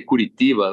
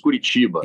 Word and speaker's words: Curitiba: When they misspell it, they say Curitiba. Like, listen, it's Curitiba.